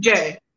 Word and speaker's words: Jaime: It's pronounced J. Some people say Jai, it's J.